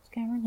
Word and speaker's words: Cameron: Die, zeg ik dat goed, Cameron? Ja.